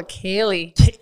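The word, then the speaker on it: ceilidh: It's called the Celid. So anyways, it's a kitchen party. So I butchered it. It's C-E-I-L-I-D-H. – Oh, a Kaylee. – Is that Kaylee? – Yeah, I know.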